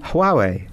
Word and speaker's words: Huawei: Huawei. How do you say it? – Huawei. – Huawei.